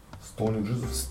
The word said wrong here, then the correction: Stoned Jesus: Stone Stoned Jesus.